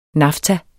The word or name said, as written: nafta